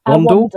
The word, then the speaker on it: Wandle: One, is it Wandle? – Wandle. – River Wandle. You know, I'm from the north side of the River, you know, River Thames in London. So of course I pronounced that wrong.